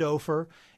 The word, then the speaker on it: Ophir: And that's spelled O-P-H-I-R. – O-P-H-I-R.